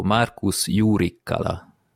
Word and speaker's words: Markus Jurikkala: Tehát, hogyha valaki nagyon hivatalosan és precízen akarja kiejteni a nevét, akkor Markus Jurikkala.